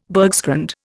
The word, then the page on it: bergschrund: Indian